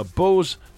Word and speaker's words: Bose: Chance to take on Robert in tomorrow's show, where you could win a fantastic Boss all-in-one sound system. A Bose. Is it Bose or Boss?